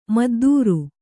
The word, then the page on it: ಮದ್ದೂರು: maddūru